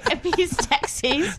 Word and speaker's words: Epistaxis: Is that how you say it? Epis. How do you say that? – Epistaxis? – Yeah. I'd say epi before I'd said epis. – Okay, epistaxis. – I don't know if epistaxis is right either.